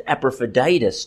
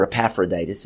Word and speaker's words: Epaphroditus: Epaphroditus in the next paragraph is also a good example of this because they sent Epaphroditus to him, or Epaphroditus, if you choose to pronounce it that way.